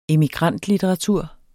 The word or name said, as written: emigrantlitteratur